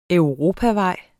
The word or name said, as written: europavej